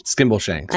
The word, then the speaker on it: Skimbleshanks: How is it in English? – Skimbleshanks.